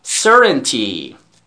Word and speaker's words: Certainty: The result is impossible to predict with any degree of certainty. Certainty. بله تلفظش همینه. Certainty. اوکی؟ تی رو دی تلفظ کن دوست عزیزم.